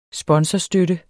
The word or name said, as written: sponsorstøtte